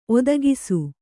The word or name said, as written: ಒದಗಿಸು